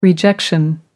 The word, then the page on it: rejection: American English